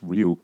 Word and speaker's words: Ryu: It's a Ryu, which is pronounced Ryu.